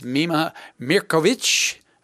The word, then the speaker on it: Mirković: is Mima Mirković. I got that right, didn't I? That's the Serbian pronunciation, because you were actually born in Serbia, but yeah.